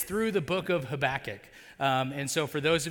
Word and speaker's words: Habakkuk: that's the way that word is pronounced. Habakkuk.